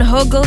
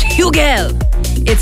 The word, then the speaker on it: Hugel: Some people are saying Huggle, some people are saying Hugel. I don't know. We got an email sent out about it, and apparently it's Hugel.